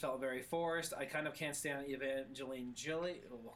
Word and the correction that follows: Evangeline Lilly: I just really butchered Evangeline Lilly.